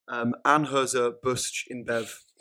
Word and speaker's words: Anheuser-Busch InBev: This December 2015, it was announced that Camden Town Brewery was being bought by the world's largest brewer, Anheuser-Busch InBev. Again, butchered the pronunciation. – That sounded right.